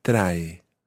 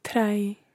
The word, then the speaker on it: trae: Take care with the word trae. It's got two syllables in it. The first is tra. – Tra. – And then the second syllable is e.